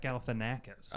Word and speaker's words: Galifianakis: How do you say his last name? – Yeah, wait. – Galifianakis? – It's like Zack Galifianakis. – It's Zack Galifianakis.